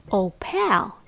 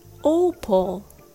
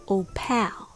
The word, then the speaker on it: opal: It was opal, not opal. So I learned the lesson and I learned how to say it right. The word Opal has the stress on the first syllable, O. So the second syllable shrinks or gets smaller and shortened. Pal becomes pull. Only the letter P sound and the L sound, P and O, pull. Just ignore the letter A in between, okay?